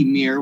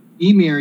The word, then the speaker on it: Ymir: The way that Harryhausen in the commentary says it is, it's almost being pronounced with the double E, Ymir, with a long E sound. – Like Ymir? – Ymir, yeah.